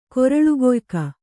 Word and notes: ಕೊರಳುಕೊಯ್ಕ: koraḷu koyka

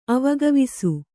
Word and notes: ಅವಗವಿಸು: Kannada

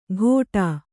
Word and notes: ಘೋಟಾ: Kannada

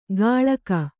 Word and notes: ಗಾಳಕ: gāḷaka

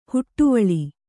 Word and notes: ಹೊಡೆವಲೆ: hoḍevaale

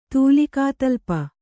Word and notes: ತೂಲಿಕಾತಲ್ಪ: Kannada

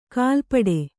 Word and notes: ಗಲಪಟ್ಟಿ: Kannada

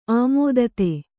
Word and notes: ಆಮೋದಿತ: āmōdita

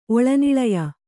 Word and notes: ಒಳನಿಳಯ: Kannada

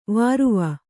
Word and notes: ವರವು: varavu

